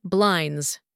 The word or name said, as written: blinds